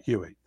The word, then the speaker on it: Huie: And his last name is spelled H-U-I-E. Sorry. – That's right. Hewitt.